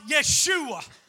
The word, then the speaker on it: Yeshua: Because when she heard the name Jesus, which is actually pronounced Yeshua, the name Jesus, the name Yeshua means to rescue, to deliver.